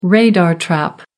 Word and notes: radar trap: American English

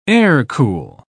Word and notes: air-cool: British English

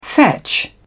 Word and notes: fetch: British English

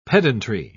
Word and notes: pedantry: British English

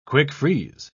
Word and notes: quick-freeze: British English

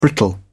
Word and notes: brittle: British English

